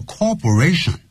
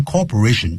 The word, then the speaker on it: cooperation: 感谢您的合作。Cooperations, C-O-O-P-E-R-A-T-I-O-N. 所以一定要念成 co,再再来一个 operation, 可是我们乘务员呢或者一些呢就是机长呢常会念 Thank you for your cooperation, 不是 corporation,